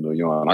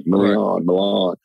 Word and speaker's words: Milian: and I was talking about Christine Milian. I couldn't even pronounce her name at the time. I was like, Milan.